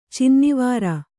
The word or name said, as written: ಚಿನ್ನವಾರ